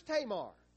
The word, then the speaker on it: Tamar: Who was Tamar? Well, Tamar was the daughter-in-law of Judah. I didn't say that wrong. I said that right.